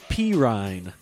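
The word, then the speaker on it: Perine: Perrine? – I believe they pronounce it P-Rine. And I'm serious about that.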